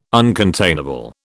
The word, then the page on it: uncontainable: British English